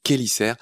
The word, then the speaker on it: chélicères: Ça s'écrit chélicères, mais ça se dit chélicères.